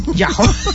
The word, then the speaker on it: Yahoo: ¿Cómo lo pronuncio yo, Yahoo? Bueno, en fin. Bueno, Yahoo.